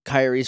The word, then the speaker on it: Kairi: I have a question about this, like, Kairi's from another world.